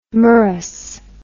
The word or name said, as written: merous